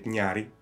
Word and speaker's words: Nyári: I say it Nyári.